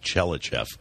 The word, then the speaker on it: Tchelistcheff: And I can't believe I finally learned how to pronounce the name, because it's spelled T-C-H-E-L-I-S-T-C-H-E-F-F, Chelyachev.